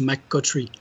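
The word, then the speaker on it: McCoughtry: c'est un piège, c'est un peu euh, parce que avec le GH, on a tendance à se dire qu'il faut faire euh, coff comme la comme la tour en anglais. Mais en fait, ça se prononce McCothry Oh, donc c'est facile en fait.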